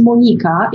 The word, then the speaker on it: Monika: My name is actually pronounced Monika in my mother country of Poland.